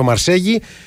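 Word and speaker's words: Marseille: Ωστόσο πιο κοντά είναι το Μαρσέγι, να πούμε ότι έτσι το λέει πάντα ο Κώστας Νικολακόπουλος. – Okay, για να είμαστε δίκαιοι. Πάντα Μαρσέγι τη λέει.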